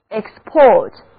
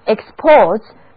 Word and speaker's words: export: 这个时候它是动词，字母组合 or 还是读作长长的 o，而字母 e 的读音是短的 e， export，单词的重音是第二个音节。那日本向许多国家出口货物，就是 Japan exports to many countries， Japan exports to many countries。